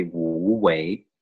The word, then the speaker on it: Wu Wei: And I, I think my pronunciation of the uh, principle it's it, it's supposed to be Wu Wei,